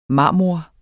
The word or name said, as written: marmor